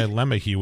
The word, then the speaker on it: LeMahieu: No, LeMay, he goes by LeMahieu, which isn't even that's not even French pronunciation of that. It's not even a like mispronunciation. It's just like I'm just going to go LeMahieu. Shut up.